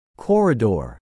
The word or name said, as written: corridor